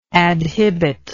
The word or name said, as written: adhibition